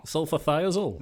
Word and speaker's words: sulfathiazole: Sulfathisiazole? Wow. Sulfathiazole. There we go. I, would, I think I, I, loved an extra syllable in there, didn't I?